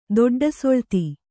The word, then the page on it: ದೊಡ್ಡಸೊಳ್ತಿ: kannada